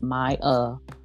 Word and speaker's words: Myah: MYAH, because I know people like to spell it Mia. It's Maya. It's spelled just how it sounds. You cannot mess that up.